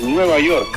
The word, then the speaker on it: Nueva York: Aquí los periodistas siempre dicen a Nueva York, y en, no sé, Nueva York, pero es Nueva York, tiene que sonar la K para que sea correctamente pronunciado el nombre de la Gran Manzana, la gran ciudad del capital del mundo, y ahora es capital del mundo, digo.